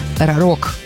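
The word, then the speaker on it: Рарог: Я не знаю, як це правильно читається. Рапор. Рарок. Так. Це найправильніше прочитання.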